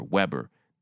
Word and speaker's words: Weber: California Assemblywoman Shirley Weber, actually, not Weber, Weber, but it's spelled W-E-B-E-R, not two B's, just one.